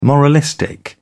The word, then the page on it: moralistic: British English